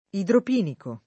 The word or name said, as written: idropinico